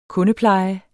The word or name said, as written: kundepleje